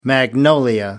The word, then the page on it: magnolia: American English